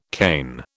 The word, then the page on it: Cain: British English